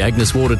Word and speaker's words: Agnes Water: Heading down to Agnes Waters. – Mate, it's pronounced Agnes Water.